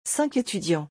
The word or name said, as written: 5 etudiants